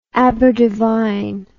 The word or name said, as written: aberdevine